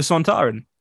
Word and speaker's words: Sontaran: The Centurion, is that what I'm saying? Am I saying that right? – Oh, the Sontaran.